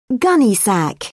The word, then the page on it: gunnysack: British English